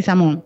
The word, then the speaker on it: Sisamone: Lo dije bien, Sisamone o Sisamón. Sí, Samón.